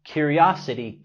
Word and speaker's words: curiosity: Remember, curiosity killed the cat. No fisgonees. Recuerda, curiosidad mató el gato. La T en curiosity lo pronunciamos tal cual la R suave en español.